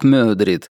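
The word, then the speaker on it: Madrid: Тут есть Мадрид, местные произносят как Мёдрид, Швеция, Норвегия, Этна, Кале, Кембридж и Франкфурт.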